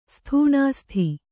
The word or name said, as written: ಸ್ಥೂಣಾಸ್ಥಿ